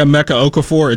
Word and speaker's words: Okafor: Like a Emeka Okafor, it's a Emeka Okafo. That's how you're supposed to say it.